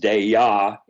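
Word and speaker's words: Dyea: he will walk people around the woods in Dai, which actually its native pronunciation is Daya in Tlingit, meaning pack trail.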